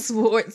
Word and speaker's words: sword: It's the W in sword silence.